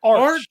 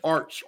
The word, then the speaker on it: Arth: It's Arch. A-R-T-H is how you say it. – Yeah, Arch.